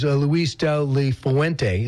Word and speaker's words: Luis de la Fuente: He said as soon as he was done playing, he went to Spain to a coaching academy, and his coach was Luis de la Fuente.